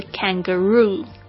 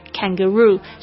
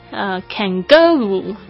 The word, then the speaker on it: kangaroo: 比如说像我们的这个澳洲的袋鼠是 kangaroo，你就会发现这个 roo 讲得好重哦，所以呢重音的确是在 roo 上面， kangaroo，所以如果你念成 kangaroo，就会有人就听不出来了。这个绝对是英语母语人士，他这个重音呢一旦放错之后呢，他就会听不出你在讲什么东西。